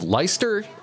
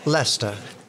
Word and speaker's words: Leicester: From University of Leicester in England. Or is it, did I say it right? – Leicester.